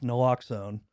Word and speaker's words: naloxone: It's naloxone. Is that how it's pronounced? – Yeah.